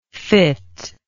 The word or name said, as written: fytte